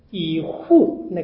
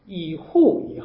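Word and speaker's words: 以笏: 以户，那个第二字读成户不是物啊，以户以后，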